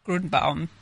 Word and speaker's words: Gruenbaum: Did I say it right? No. Please correct me. Gruenbaum. – Gruenbaum. Yes.